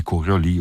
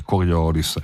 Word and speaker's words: Coriolis: di Coriolis o di Coriolis, a dipendenza di quale pronuncia vogliate adottare.